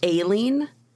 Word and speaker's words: Aileen: So, Eileen Wuornos. I've also heard it pronounced Aileen, spelled A-I-L-E-E-N, Aileen Wuornos.